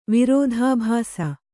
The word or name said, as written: ವಿರೋಧಾಭಾಸ